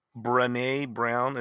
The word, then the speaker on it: Brené Brown: Brené Brown is like Rene, but with a B in front.